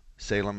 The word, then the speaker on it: Salem: Salem is spelled like the cigarette, for those of you old enough to remember that, and those of you young enough, like Salem Witches, which isn't really talking about my sisters, but close enough. S-A-L-E-M.